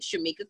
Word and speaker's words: Charmica: If you want to connect to me on Instagram or Facebook, you can connect with me with my name, Charmica Curry. I'll spell it for you because most people don't get it right. Charmica Curry.